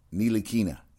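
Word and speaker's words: Ntilikina: I haven't heard from Ryan what he thought of, is it, Nicolini? You know what? I'm going to butcher that name for the rest of my life. I need to put that in my Rolodex of... Yeah, I'm trying to... I want to pronounce his name right. I'm sorry. No. Nilikina.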